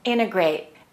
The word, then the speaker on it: integrate: Most of the time, native speakers do not pronounce the T of the word integrate. They say integrate, and that means to combine one thing with another thing to make a whole.